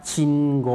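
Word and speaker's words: ชิ ง โ ก (信号): ออกเสียงว่าโกโกอุเนี่ยจะออกเสียงรวบยาวนะครับออกเสียงว่าโกเพราะฉะนั้นคันจิ 2ตัวแรกนะครับเป็น 1คำนะครับออกเสียงว่าชิงโกชิงโกครับ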